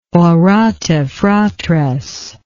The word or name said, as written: orate fratres